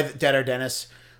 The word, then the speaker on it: Dead Air Dennis: I clearly have a nickname that I use, Dead Air Dennis. Clearly, I wasn't born Dead Air, or as some people have mispronounced it, Diadier? Is that yeah, French? – Sure. – I go by Dead Air Dennis.